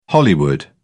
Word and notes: hollywood: British English

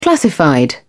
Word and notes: classified: British English

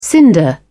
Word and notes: cinder: British English

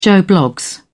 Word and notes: joe bloggs: British English

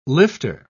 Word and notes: lifter: British English

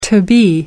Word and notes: to-be: American English

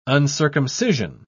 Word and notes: uncircumcision: British English

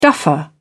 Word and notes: duffer: British English

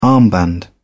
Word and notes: armband: British English

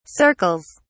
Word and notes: circles: British English

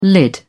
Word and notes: lid: British English

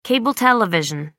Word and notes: cable television: American English

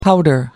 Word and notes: powder: American English

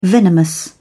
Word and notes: venomous: British English

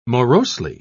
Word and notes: morosely: British English

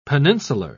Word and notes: peninsular: British English

